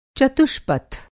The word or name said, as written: चतुष्पथ